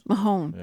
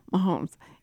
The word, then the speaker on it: Mahomes: How do you say his name? – Mahomes. – Yeah. Mahomes. Yeah.